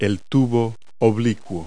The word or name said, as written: down tube